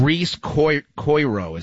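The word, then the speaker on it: Rhys Coiro: I think it's Reese Coiro is how you say his name.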